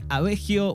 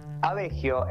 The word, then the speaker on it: Aveggio: Abegio, en realidad lo puedes pronunciar de las dos formas, porque es un apellido italiano, o sea, está bien decirle Abegio, pero acá en criollo se dice abegio.